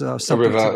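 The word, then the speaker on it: Rievaulx: So Aelred, A-E-L-R-E-D of, I don't know how to say it, but Rivaux, I guess. – Rivaux, that's what I say too.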